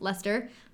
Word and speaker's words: Leicester: Same thing with Leicester, it's pronounced Lester.